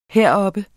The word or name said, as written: heroppe